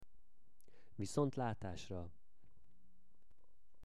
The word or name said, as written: viszontlatasra